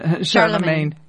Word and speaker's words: Charlemagne: Justinian and Charlemagne. – Charlemagne.